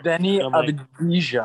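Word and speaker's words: Deni Avdija: Denny Avdija.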